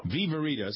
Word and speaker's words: Viverito: Break into syllables. – Yeah, it's easy enough. – It is. I mean, you don't have to be a genius. A little Italian helps, but other than that, Viverito.